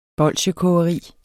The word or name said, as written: bolsjekogeri